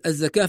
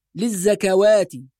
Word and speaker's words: الزكاة: بعض الناس لما ينظر إلى كلمة الزكاة في المصحف يظن أن فيها واو منطوقة فيقول مثلا للزكوات، وهذا خطأ، لأن هذه الواو هي واو في الرسم فقط، يعني ثابتة في الرسم، ولكن النطق يكون بألف.